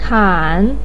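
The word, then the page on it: kan: tone 3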